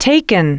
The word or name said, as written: taken